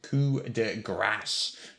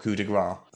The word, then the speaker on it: coup de grâce: Mardi Gras, however, is spelled G-R-A-S, and yes. That means fat. – Fat Tuesday. – Good Mardi Gras. Grass is G-R-A-C-E, which is a French word meaning mercy. So you don't want to say blow of fat. You want to say blow of mercy. It's pronounced coup de grâce, not coup de gras.